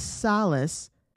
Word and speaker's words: Salas: We got Dallas Salas, I believe is how you say his name, is an amazing young man.